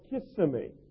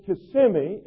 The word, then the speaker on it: Kissimmee: It's spelled K-I-S-S-I-M-M-E-E. And most people from out of state or out of country call it Kissimmee. And we tell people it's Kissimmee in the daytime and Kissimmee at night.